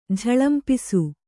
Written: ಝಳಂಪಿಸು

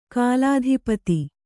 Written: ಕುಲಾಧಿಪತಿ

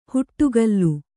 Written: ಹಿಡಿಗೋಲು

